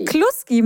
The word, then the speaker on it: Kluski: Ja, klar, jetzt gibt es gleich Kloskis. – Kloskis? Das ist die polnische Version übrigens. Das heißt nicht Klöße, sondern Kloskis. – Kloskis. – Habe ich richtig ausgesprochen? Kloski? – Kloski. Kluski mit U.